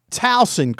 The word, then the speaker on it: Towson: And they said to me before, as we were starting, now it's Towson, correct? And I said, yes. we pronounce our S's properly, unlike you in North Dakota State who can't say the word bison and call them bison.